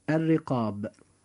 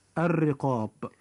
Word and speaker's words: الرقاب: وكلمة الرقاب جاء كذلك حرف الراء مكسورا فيجب أن يرقق فينطق هكذا الرقاب. – الرقاب.